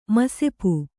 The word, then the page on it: ಮಸೆಪು: masepu